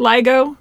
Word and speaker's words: LIGO: Explain how something like the arms of the is it LIGO? – Mm-hmm.